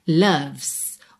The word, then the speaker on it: loves: Atenção aí à pronúncia de loves. Não é loves e nem loves, tá? Loves. O O tem som de A e o E não é pronunciado.